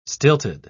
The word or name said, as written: stilted